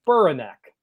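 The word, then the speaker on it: Spiranac: Texter says, you guys' pronunciation of Spiranek is hilarious. It's pronounced Spiranek.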